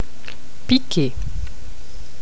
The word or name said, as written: piquer